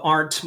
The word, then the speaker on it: Arndt: So if you look up Rob Arndt, my last name is spelled A-R-N-D-T.